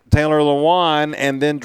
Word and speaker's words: Lewan: Lewan, Taylor Lewan.